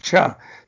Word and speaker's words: Choi: We have everybody in America pronouncing Choi the way it's supposed to be in Korean, Cha.